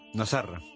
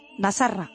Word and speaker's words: Nasarra: Bueno, pues vamos a empezar explicando el título del documental, que sí ya nos dirá cómo se pronuncia, Nasarra. – Nasarra. – Nasarra, así, Nasarra, significa nazareno en árabe.